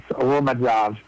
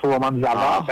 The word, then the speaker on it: romazava: So that's the first one is romazav, R-O-M-A-Z-A-V-A. There's a lot of words in Malagasy, you don't pronounce the last letter. So here it's romanzava, but you just say romanzav.